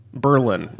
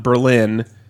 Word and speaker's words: Berlin: which is pronounced Berlin, not Berlin, because in New England, they refuse to pronounce anything the way you would expect.